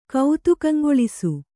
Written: ಕೌತುಕಂಗೊಳಿಸು

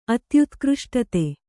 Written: ಅತ್ಯುತ್ಕೃಷ್ಟತೆ

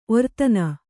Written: ಒರ್ತನ

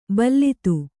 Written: ಭಲ್ಲಾತ

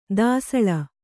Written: ದಾಸಳ

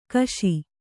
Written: ಕಶಿ

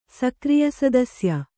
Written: ಸಕ್ರಿಯಸದಸ್ಯ

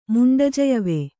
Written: ಮುಂಡಜಯವೆ

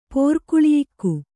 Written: ಪೋರ್ಕುಳಿಯಿಕ್ಕು

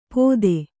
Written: ಪೊತ್ತೆ

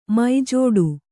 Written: ಮಜಡು

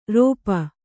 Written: ರೋಪ

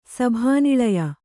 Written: ಸಭಾನಿಳಯ

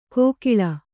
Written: ಕೋಕಿಳ

